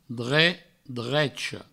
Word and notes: drech, drecha: dre, drecho; Provençal

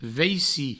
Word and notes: vaici: veici

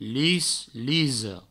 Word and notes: lis, lisa: provençal; lis, liso

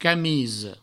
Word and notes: camisa: provençal; camiso

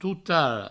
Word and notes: totara: tout-aro

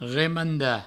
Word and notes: remandar: remanda

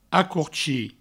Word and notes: acorchir: acourchi

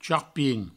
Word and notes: charpin: provençal

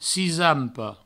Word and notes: cisampa: cisampo; provençal

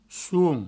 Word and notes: som: souom

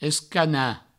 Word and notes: escanar: escana; provençal

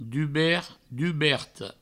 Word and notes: dubert, duberta: dubert, duberto; Provençal

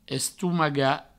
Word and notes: estomagar: Provençal; estoumaga

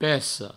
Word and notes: peca: peco; provencal

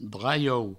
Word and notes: draiou: Provençal

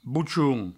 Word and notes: bochon: bouchoun

